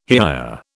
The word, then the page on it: hiya: British English